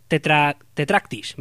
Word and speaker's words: Tetractis: este Tetrakitis, que es, vamos a, lo voy a pronunciar mejor, Tetractis, mejor dicho, vamos a ver, joder, como tengo y la lengua.